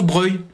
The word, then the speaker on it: Broglie: C'est comme la place Breuil à Strasbourg. Ça s'écrit Broglie, mais ça se prononce Breuil.